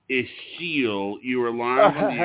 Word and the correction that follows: Ashil: It's Ashiel, actually. Ashiel, A-S-H-I-L. – Ashiel.